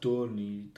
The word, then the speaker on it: Dagny: It's pronounced Dorni. Dorni. – Dorni. – Dorni. Sorry, yeah. Dorni. I've only seen it type. Well, no, we've... – There's, the G is different. It's like Dorni.